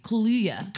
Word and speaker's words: Kaluuya: And Daniel Kaluuya as well. – That's right. Kaluuya.